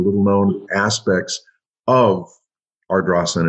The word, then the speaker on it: Ardrossan: Um, I know that you were born, as we discussed in Ardrossan, and Ayrshire.